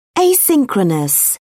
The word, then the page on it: asynchronous: British English